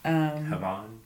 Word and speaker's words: Havane: Havane. – H-A-V-A-N-E.